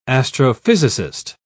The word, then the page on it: astrophysicist: British English